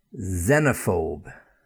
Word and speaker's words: xenophobe: Xenophobe. Again, begins with the letter X, Z sound, xenophobe.